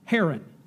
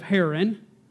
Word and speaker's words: Haran: Haran, H-A-R-A-N, or Haran, as people pronounce it nowadays.